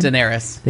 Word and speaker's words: Daenerys: Daenerys. – They said, well, we apologize for the mistake. Daenerys didn't order a latte.